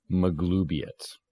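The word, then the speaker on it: Maglubiyet: So, to be clear on the pronunciation of that, it's Ma-Glue, like horse glue. – Oh. – Magloobiet.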